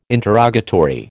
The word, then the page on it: interrogatory: American English